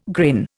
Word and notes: grin: Indian

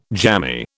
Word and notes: jammy: British English

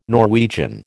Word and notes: Norwegian: American English

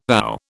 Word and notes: ow: British English

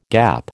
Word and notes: gap: American English

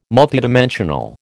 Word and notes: multidimensional: American English

